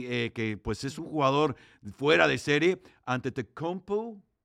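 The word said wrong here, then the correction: Antetokounmpo: ante Tocumpo, lo dije bien, fíjate nada más. Me dieron el dedo pulgar hacia arriba.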